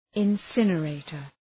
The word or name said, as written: incinerator